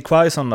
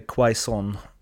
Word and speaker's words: Quaison: Och där om vi ska gå över till Quaison då, eller Quaison, eh, beroende på eh, hur man väljer att uttala det.